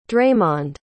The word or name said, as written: draymond